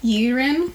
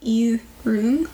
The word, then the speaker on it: Yirun: Because when you look at it, you'd think it's like Yirin. – Mm-hmm. – But it's Yirun.